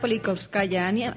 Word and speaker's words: Politkovskaya: Politkovskaya. No sé si lo he dicho bien, Clara. – Exacto, Ana Politkovskaya.